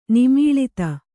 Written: ನಿಮೀಳಿತ

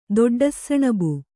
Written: ದೊಡ್ಡsಸಣಬು

